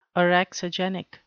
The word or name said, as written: orexigenic